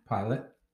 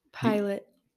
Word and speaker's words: Pilate: Pilate. – Pilate, the Roman governor.